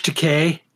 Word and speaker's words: Takei: I mean, to get George Takei, cause it rhymes with toupee, to retweet, to re-share it.